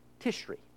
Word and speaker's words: Tishri: The seventh month is pronounced Tishri.